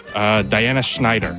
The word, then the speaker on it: Schneider: To Diana Schneider.